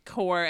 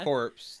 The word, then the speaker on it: corps: But no, you mean core as in C-O-R-P-S. – Yeah, I actually said it right instead of corpse that I normally would.